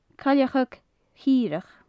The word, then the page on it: cailleachag chireach: gaelic